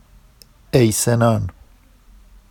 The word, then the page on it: ejsenɑn: Vafsi